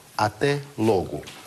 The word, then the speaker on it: até logo: То есть, когда на слово не падает ударение, вот сюда падает «лог», а сюда не падает, значит, читается ближе к «у». «Логу». «Логу». «Ате логу».